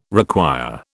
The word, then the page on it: require: British English